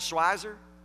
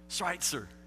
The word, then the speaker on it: Schweitzer: but in Franklin they call it Schweizer because it's Franklin, Schweizer. It's a long, long time till I understand that that community, if you pronounce it correctly, would be called Schweitzer.